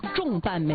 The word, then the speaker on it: 重瓣: 啊，但不是，就是它的标准读音读重，重瓣玫瑰。